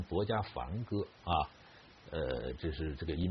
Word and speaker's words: 薄伽梵: 那么我在学佛的时候呢，经常会在佛经里看见这个词啊，比如说很多经啊，佛经一开篇就是如是我闻啊，一时啊薄伽梵啊，那个时候呢，我们在佛教界里边，他们读成薄伽梵啊，一时薄伽梵啊，住王舍城，鸠峰山顶，与大比丘啊，众千二百五十人聚啊，经常会看到这样的句子。